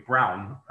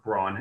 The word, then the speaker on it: Braun: Dieter Rams of the company Braun, or Braun if you're American, Braun.